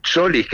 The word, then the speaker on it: Tzolis: tiene un griego Solis, que se escribe T-Z-O-L-I-S, Solis, que está muy bien, pero Julián obviamente tiene otra capacidad y podría jugar perfectamente sobre la izquierda.